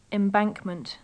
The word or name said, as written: embankment